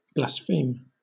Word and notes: blaspheme: British English

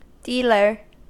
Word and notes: dealer: American English

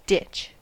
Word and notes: ditch: American English

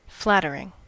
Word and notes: flattering: American English

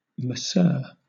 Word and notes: masseur: British English

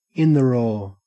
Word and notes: in the raw: Australian English

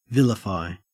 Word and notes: vilify: Australian English

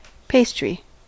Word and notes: pastry: American English

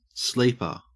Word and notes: sleeper: Australian English

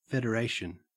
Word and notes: federation: Australian English

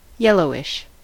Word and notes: yellowish: American English

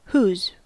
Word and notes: whose: American English

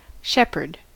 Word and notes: shepherd: American English

